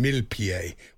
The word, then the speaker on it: Millepied: But I thought to myself, I wonder if that's really how you pronounce his name. So I looked it up last night. It isn't at all. It's Benjamin Millepied, which doesn't work at all, really.